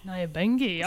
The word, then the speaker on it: Nyabingi: We're talking about, I'm not sure if I'm pronouncing it correctly, Nyabingi. – Nyabingi, okay. – Sounds right.